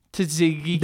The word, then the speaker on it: Tzatziki: Tzatziki. – There's the T is silent. – Tzatziki. Yeah. – Well, it's not really silent. It's not a T. – It's a tz.